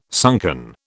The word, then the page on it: sunken: British English